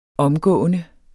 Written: omgående

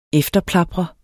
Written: efterplapre

